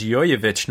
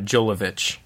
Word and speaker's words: Jovovich: Dude, Mila Jovovich is pretty good, and I learned from the commentary that her last name is pronounced Jovovich, not Jolovich.